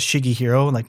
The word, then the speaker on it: Shiggehiro: So before I was adopted, my birth name was Shiggy Hiro, for my last name. And so growing up, a lot of people had no clue how to say it. It's spelled Shiggehiro.